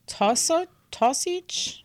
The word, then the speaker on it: Taoiseach: The Taoiseach, I'm going to look. – How do you spell it? – T-A-O-I... S-E-A-C-H.